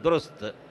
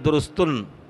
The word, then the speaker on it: درست: فسين أيضا الساكن والتاء موقوف، هكذا باللغة الأردية والفارسية يهجون، دام دال ضمة وراء ضمة وسين ساكن وتاء موقوف، درست، لا يقول درستن، لا، كل يعني كلمة ينبغي أن تطلق على نطقهم، إلا إذا كان العرب غيروه ببعض الكلمات وعربوه.